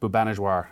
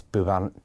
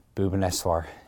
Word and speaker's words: Bhubaneswar: What do you know about Bhubaneswar? Am I pronouncing that right? – Almost, yeah. I think it's Bhubaneswar. – Bhubaneswar, yeah.